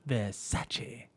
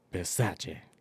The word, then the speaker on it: Versace: Ah, Versace. Can you not say it like that? – Versace.